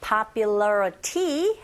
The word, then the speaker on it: popularity: 자, 일단 앞에서 연습하셨던 valley 긁어주시고 that gained, 여기서 발음 gained, popularity 해도 되는데 아시죠? 철자는 Y로 끝났지만 Y가 소리가 E라는 모음이고 앞에 T 앞에 또 I가 보이시죠? 또 모음과 모음 사이에 네, T가 껴있습니다. 이런 경우 T소리가 R소리로 들려지게 되죠.